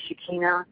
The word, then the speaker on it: Shekinah: Shekinah, it's pronounced Shekinah, it's also Shekinah,